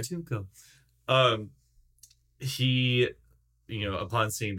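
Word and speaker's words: Katuunko: That this King Katunko. Double check the pronunciation of his name. – The Toydarian King. – He has a name. People appreciate when you call him by their name.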